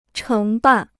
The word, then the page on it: 承办: chéng bàn